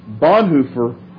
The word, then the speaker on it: Bonhoeffer: There's a sense in which Bonhoeffer... And that's how they pronounced it, by the way. I know it sounds weird.